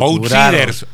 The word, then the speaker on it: outsiders: Veo que andan nombres dando vueltas y gente que se está reuniendo para el outsiders, outsiders. – Sí. – ¿Se dice así? – Exacto. – Outsiders.